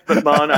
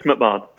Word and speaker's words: McMahon: It's McMahon. I know it's spelled McMahon, but it's McMahon.